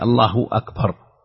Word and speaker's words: الله أكبر: لا شك أن الصيغة هي الله أكبر، الله الهاء مضمومة لفظ الجلالة، وأكبر الهمزة مقطوعة، همزة قطع، همزة قطع، الله أكبر، هذا هو الأصل في الصياغة.